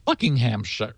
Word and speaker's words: Buckinghamshire: He's in Waddesdon Village, Buckhamshire, or is it Buckinghamshire. – Buckinghamshire. – Buckinghamshire.